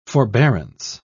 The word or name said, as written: forbearance